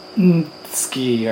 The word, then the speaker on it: NTsKi: So let's start this next section with Yuragi, then followed by Jirina, and then followed by Ntski or Ntski.